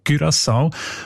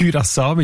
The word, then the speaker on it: Curaçao: Die haben nämlich gerade letzten Dezember die Aussprachempfehlung geändert von Curaçao auf Curaçao. Weil sie beziehen sich dort auf eine Aussprachenduden und auf das digitale Wörterbuch von der deutschen Sprache, wo beide eben Curaçao vorschreiben. – Danke vielmals. Jetzt hast du mir den Namen von der Insel gesagt, wie man den ausspricht. Curaçao mit Ü in dem Fall.